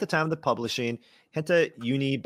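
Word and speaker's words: hentai: If you read the headline, it says Nintendo eShop's front page currently features a Henta game. Did I say that correctly? – Yeah, Henta.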